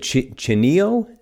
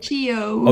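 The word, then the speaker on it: Chío: So you said Chinio, is that correct? – Chio. – Oh, Chio. How do you spell that? – It's C-H-I with a like accent O.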